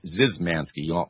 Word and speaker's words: Zemanski: He actually pronounces his name Zizmanski, although it's written Zemanski.